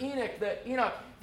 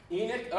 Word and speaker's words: Enoch: Without faith, we're going to learn next week from the life of Enoch, or as the Hebrew people say, Eno. I heard a guy say that yesterday, Eno. How do you say it, man? – Enoch.